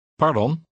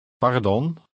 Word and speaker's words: pardon: دون دون پر پر پردون پردون. – توجه کنید که حرف ر در هلندی با لرزش خفیفی ادا میشه.